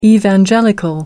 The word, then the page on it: evangelical: American English